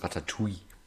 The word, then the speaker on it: Ratatouille: Richtig ausgesprochen ist Ratatouille. Dieses Doppel-L im Französischen spricht man wie I.